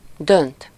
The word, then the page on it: décider: [de.si.de]